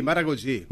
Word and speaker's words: Maragogi: En Brasil, Maragogi. – Mira. Usted queda, mira el portugués. Mira. – Mira qué hermoso. – Está bien pronunciado, ¿no? Maragogi. – Sí, Maragogi. Maragogi.